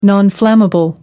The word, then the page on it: nonflammable: British English